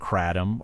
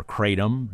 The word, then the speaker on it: kratom: The three of them and others are behind an investigation published by the Tampa Bay Times looking at kratom or kratom. Both pronunciations apparently are acceptable.